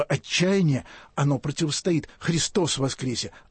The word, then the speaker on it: Христос воскресе: И не случайно на Пасху в Русской Православной Церкви не говорят «Иисус воскрес», а говорят «Христос воскресе». И вот это вот ударение на «о» я не специалист фонетики, но это все преображает.